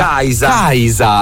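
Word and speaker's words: Kiesza: Il nome d'arte della sì, cantante canadese Kiesa si pronuncia Kaisa.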